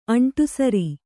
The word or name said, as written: ಅಂಟುಸುರು